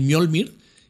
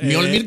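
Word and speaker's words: Mjölnir: Mjolnir, que nosotros lo hemos dicho Holnir siempre. – Holnir, porque la J... Es eso. – Sí, no sabemos que los... – MJ Holnir. – Los escandinavos pronunciaban la J como una I, ¿no? – Sí, bueno, y además eso, que mejor...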